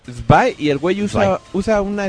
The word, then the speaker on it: Zwei: Bueno, pero está el Sway, el puto ese. – Sway. – Es que en el juego le dicen así como super alemán, Spy. Spy. Porque aparte se escribe z.w.e.i.